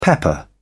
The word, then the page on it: pepper: British English